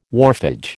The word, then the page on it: wharfage: American English